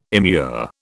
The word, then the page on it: immure: British English